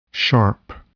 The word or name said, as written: sharp